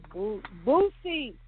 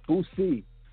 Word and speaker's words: Boosie: That's not how you say it? – Boosie. – Boosie. – Yes.